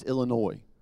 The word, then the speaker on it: Illinois: By the way, it's Illinois. The S is silent. Just to give you a little lesson.